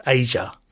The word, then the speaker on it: Aja: So it is, well, I believe it's pronounced Asia. – Right. – As some kind of Asia-ish thing. But it's spelled A-J-A, Adja. – Yeah. – And the middle bit of Kajagoogoo.